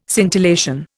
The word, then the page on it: scintillation: Indian